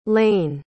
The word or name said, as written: Laine